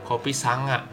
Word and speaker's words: Kopi Sanga: Nih, uh, Pospak mm, ngasih saran nih di kopi San 9A santai bukan gitu bacanya maksud gua kopi sanga